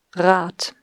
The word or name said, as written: Rad